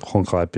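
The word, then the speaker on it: Gonggrijp: Äh, Rob Hongreib, habe ich das jetzt richtig ausgesprochen? Ronchreib, ja. Ronchreib.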